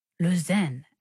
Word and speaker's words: Lausanne: Lausanne, actually. I think this is a French ensemble, so the French pronunciation would be Lausanne.